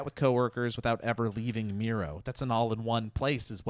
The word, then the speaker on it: Miro: And Miro rhymes with hero.